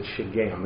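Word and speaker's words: Shiggaion: Well, we're told it's a Shegeum. I looked that up for the benefit of myself and you. It's a Shegeum. That's how you pronounce it. At least that's how it says it.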